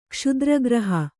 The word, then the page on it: ಕ್ಷುದ್ರಗ್ರಹ: kṣudra graha